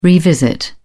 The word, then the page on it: revisit: British English